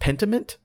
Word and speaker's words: Pentiment: And the second game comes from Obsidian, codenamed, is it Pentiment? Is that the word? – Sounds like you're pronouncing it right.